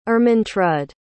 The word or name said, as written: Ermentrud